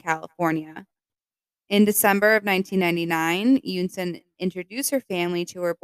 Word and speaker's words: Eunsoon: In June of 2002, Terry's current girlfriend, and I'm so sorry if I say her name wrong, it's spelled E-U-N-S-O-O-N. Yunson? – Sounds right. – Yunson Jun. She was a chemist from California. – The N is silent. It's like Yunson.